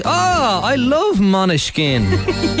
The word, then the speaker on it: Måneskin: It's pronounced, well, it's spelled Måneskin, but it's actually pronounced Måneskin.